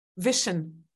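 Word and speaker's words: Vishen: I mean, like what you and your, is it pronounced Vishan? I mean, I want to make sure I'm okay. – Vishan. – Vishan. See, I said it wrong.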